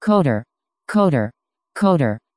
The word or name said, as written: qoder